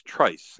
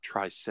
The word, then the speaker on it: Trice: This is, it's Trice, not Trice or nothing French.